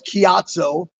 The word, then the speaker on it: Ciatso: It's Ciatso, as yes. in low key.